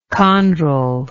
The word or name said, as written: chondral